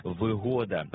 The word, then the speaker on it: вигода: Перше із них, що викликає у нас здивування – «вигода». «Вигода» має значення «зручність». Наприклад, «квартира з усіма вигодами», а не «вигодами». Це трискладовий іменник із наголосом на другому складі.